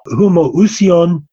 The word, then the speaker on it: homoousion: And in Greek, there are two words that are very closely pronounced. One is homoousion, and the other is homoiousion. Homoousion means the same being the homoiousion means a like being.